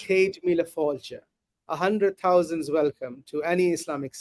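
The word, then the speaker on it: Céad míle fáilte: Cade Mila Falcha. – Cade Mila Falcha.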